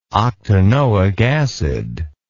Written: octanoic acid

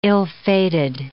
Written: ill-fated

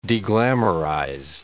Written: deglamorize

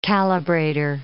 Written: calibrator

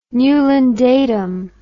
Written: newlyn datum

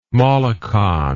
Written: Molokan

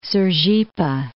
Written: Sergipe

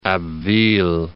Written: Abbeville

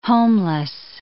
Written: homeless

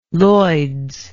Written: Lloyds

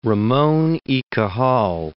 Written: Ramon y Cajal

